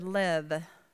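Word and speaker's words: lev (לב): In Hebrew, the word heart is the word lev. Sometimes it's spelled with a B, because that Hebrew letter is has a kind of a mixture between a V and a B.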